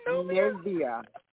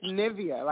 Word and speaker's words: Nivea: Yeah, it's Nivea. It's Nivea, like the lotion. – Olivia. – Uh huh. – You said Nivea? – Nivea, yes. Like the singer Nivea. – Oh, Nivea!